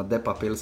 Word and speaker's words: de Papel: Ne, uh, ne bova dala spoiler alert za uh, serijo uh, Money Heist oziroma La Casa del Papel, ne, oziroma De Papel, se, ne, se to izgovori.